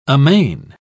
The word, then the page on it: amain: British English